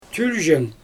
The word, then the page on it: tylʒeng: Xyzyl